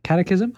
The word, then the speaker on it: catechism: Catechism? How do you say it in English? – Catechism.